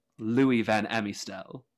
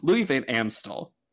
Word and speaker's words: Louis Van Amstel: Sorry, that's a terrible pronunciation. Louis Van Amstel. – Yeah. – I don't know why. I always try to put an I in his name. He doesn't need one in his last name.